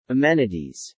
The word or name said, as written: amenities